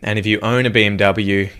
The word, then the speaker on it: BMW: In English, people say BMW. In German, we say BMW because W is pronounced W in German.